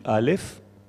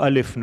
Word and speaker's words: Aleph: Le Aleph ne se prononce pas. Le Bet, c'est la lettre la plus prononcée. Il y a presque une guerre entre les deux lèvres pour sortir le B. Alors que le Aleph est muet. On n'entend pas la lettre Aleph.